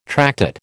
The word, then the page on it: tractate: American English